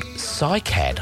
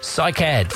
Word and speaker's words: Cycad: We're on to a Melbourne four-piece band now called Cycad. I think that's the way it's pronounced, C-Y-C-A-D.